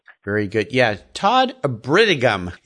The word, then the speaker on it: Bridigum: That's no, it's, it's, when you look at it, you don't want to say it the way it's spelled. B R I D I G U M, a Bridigum.